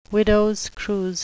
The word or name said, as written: widow's cruse